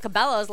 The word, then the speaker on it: Cabello: I just realized that I was saying Camila Cabello's last name wrong.